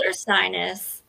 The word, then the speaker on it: Ursinus: Is it Ursinus? Is that how you say It's called Ursinus.